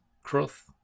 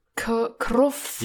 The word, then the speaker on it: Crwth: Könnte es nicht sein, fällt mir gerade ein, dass das W wie ein U ausgesprochen wird in manchen Sprachen? Ja, warte, vielleicht habe ich jetzt. – Also, dass es dann ein Kruth ist. Kruth.